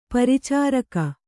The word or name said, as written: ಪರಿಚಾರಕಿ